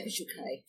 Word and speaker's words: visual kei: Can we talk briefly, if you don't mind, about visual kai? Visual kai, have I said that correctly? – Yep. Yeah, visual kei.